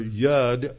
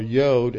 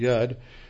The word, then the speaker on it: yod: For example, the, when he says the word jot, this is the Hebrew letter yud or yod, as it's sometimes pronounced. Modern Hebrew pronounce it yud.